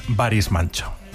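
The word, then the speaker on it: Barış Manço: Claro, este era uno de los dos hippies a los que les fue muy bien y su nombre, un poco mejor pronunciado, es Baris Manço.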